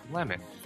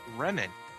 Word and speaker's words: Remon: and it's actually Remon. It's spelled R-E-M-O-N. It's kind of like it, just... Lemon with an R, but yeah, I confirmed this with Anime News Network.